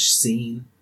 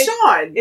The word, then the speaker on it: Sean: I also thought Sean was seen.